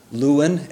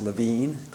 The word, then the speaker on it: Lewin: There's a social psychologist, a famous social psychologist in the 30s and 40s named Kurt Lewin, L-E-W-I-N, although pronounced Levine, Kurt Levine.